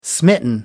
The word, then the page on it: smitten: American English